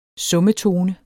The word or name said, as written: summetone